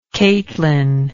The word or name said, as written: Caitlin